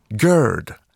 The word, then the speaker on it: Gerd: Hur uttalade britterna det? – Gerd. – Gerd. – Mm. – Ah. – Så egentligen är det Gerd. Men sen så liksom, i den här raddan så blev det Gerd.